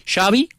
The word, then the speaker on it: Xavi: Entonces Xavi dice, Xavi como chocolate.